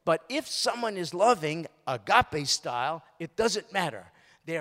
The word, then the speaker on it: agape: It looks like agape, doesn't it? But it's actually in the Greek pronounced agape.